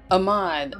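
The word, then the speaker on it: Ahmaud: It's Ahmed, right? Am I saying that right? Okay. – Say that last part. – Ahmed. I'm saying his name right. I'm saying his name correctly. Is that, I'm pronouncing it correctly. Ahmed. – Ahmad. – I'm sorry. – It took me. No, it's okay. It took me a minute. I was like, wait. Ahmad, yes.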